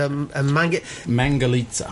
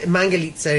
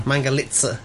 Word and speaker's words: Mangalitsa: Mangalitsa. Mangalitsa in Hungarian. – Yeah. – Mangalitsa in yeah. American.